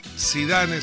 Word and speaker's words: Zidane: Zidane se escribe, y en francés, Hugo, la E final no se pronuncia.